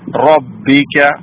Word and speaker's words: ربك: റബ്ബിക റബ്ബിക എന്നല്ല റബ്ബിക റായിന് ഫതഹ് റബ്ബിക